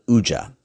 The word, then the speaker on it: UJA: So long story short, she'd called Uja. Yeah, Uja. What's Uja? UJA, when you pronounce it.